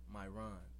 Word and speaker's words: Myron: Is your name is pronounced Myron? – Myron. Like your Ron.